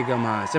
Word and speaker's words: Gomas: With all that being said, I want to welcome on the lovely, the beautiful, the talented, the everything, Tiffany Gomas. Am I saying that right, Tiff? – Tiffany Gomas, yes. – Gomas, okay, I want to. – There's no Z.